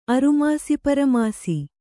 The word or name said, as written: ಅರಮಾಸಿಪರಮಾಸಿ